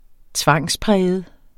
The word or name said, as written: tvangspræget